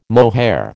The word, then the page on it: mohair: American English